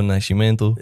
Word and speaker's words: Nascimento: Ja, perfekt portugiesisch ausgesprochen. – Ja. Do Nascimento. – Ja, schon besser.